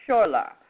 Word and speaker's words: choler: Now, the synonyms for that is anger, rage, fury, vexation, and, of course, crossness, displeasure, annoyance, irritation, ire, and or it says I believe it's pronounced shorler, which is C-H-O-L-E-R, unpleasantly rough or jarring to the senses.